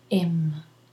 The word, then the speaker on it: him: Ask him is pronounced ask him without the H. When we speak fast, we say he wants, he wants to ask him. He wants to ask him. When you pronounce slowly, you can say he wants to ask him, him, not im, him.